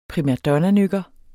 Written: primadonnanykker

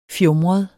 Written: fjumret

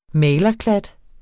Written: malerklat

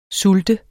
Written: sult

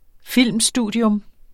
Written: filmstudium

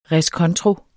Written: reskontro

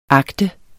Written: agter

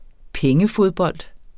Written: pengefodbold

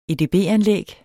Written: edb-anlæg

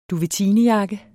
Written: duvetinejakke

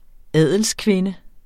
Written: adelskvinde

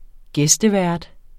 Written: gæstevært

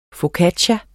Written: focaccia

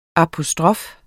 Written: apostrof

